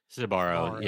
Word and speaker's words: Sbarro: Versus, now, make sure I'm pronouncing this correctly. Is it Sbarro? – Sbarro, yeah.